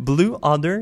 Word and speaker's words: Blue Odor: Blue Odor, c'est comme ça qu'on prononce.